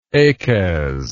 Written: achaz

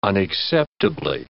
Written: unacceptably

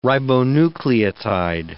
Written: ribonucleotide

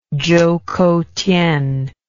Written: choukoutien

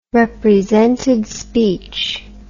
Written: represented speech

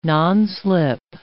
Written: nonslip

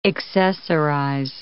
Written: accessorize

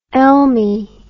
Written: elmy

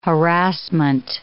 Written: harassment

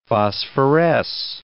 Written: phosphoresce

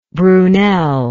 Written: brunel

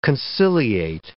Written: conciliate

